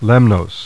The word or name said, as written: Lemnos